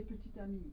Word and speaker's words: petit: So you don't pronounce the second T, you don't say petit. – You say petit ami.